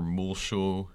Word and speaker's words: Muleshoe: Another one is Victor Leal, former Muleshoe mayor. – that's the Indiana pronunciation of mule shoe. It's a mule and a shoe. This is not hard.